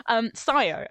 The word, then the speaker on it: Sayo: I just wanted to correct you. – Sayo, okay. – Yeah, that's Shannon's... name Shannon uses when not at work. – Her actual name. – I can't believe I pronounced it like Mayo.